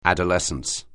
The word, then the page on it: adolescence: anglais